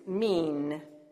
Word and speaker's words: min: Now, the word for kind in the Hebrew is mean. Like, wow, you're really mean, except it's spelled M-I-N.